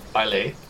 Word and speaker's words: Baile: It's spelled Baile. Bile.